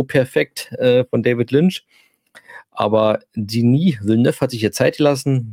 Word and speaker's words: Denis Villeneuve: Und jetzt die Denis, wird da Denis Villeneuve ausgesprochen eigentlich? Ist ja französisch, ja. Denis, ja. Nicht Dennis oder sondern Denis Villeneuve.